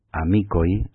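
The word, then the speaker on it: amikoj: Amicoj se escribe con K y acabado en J, significa amigos en esperanto, y se pronuncia amicoj, @teleline.es.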